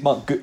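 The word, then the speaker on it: Guiu: I mean, I've heard Mark Giu, but I think it's Mark Giu. Essentially, it's Giu. Mark Giu.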